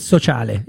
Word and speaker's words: Sociale: Yeah, most of our guests have a hard time to pronounce our name because it's Sociale. You pronounce it perfectly, but the final E is very confusing to a lot of people.